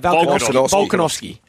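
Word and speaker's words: Volkanovski: Hero, I'm going with Alex Valkanovski. How do I pronounce that? – Valkanovski.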